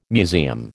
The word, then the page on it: museum: American English